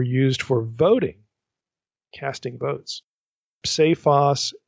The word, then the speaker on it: psephos: The Greek here is psephon leucain, and Owen writes this, psephos, that's P-S-E, long E, P-H-O-S.